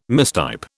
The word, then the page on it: mistype: British English